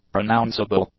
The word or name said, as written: pronounceable